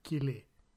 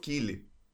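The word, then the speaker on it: Keeley: πω, προφέρεται Κίλι. – Ναι, είναι, είναι Τζοφ Κίλι.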